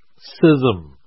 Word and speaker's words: schism: I used to talk about schism until a dear brother gently corrected me that it is pronounced schism. There is no sound. And so schismatics cause schisms in the church. That's the proper way to say the word.